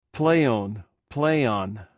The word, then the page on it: pleiwn: greek